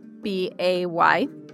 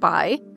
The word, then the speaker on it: Bay: B A Y. So it looks like Bay, but you pronounce it Bai.